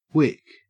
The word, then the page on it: wick: Australian English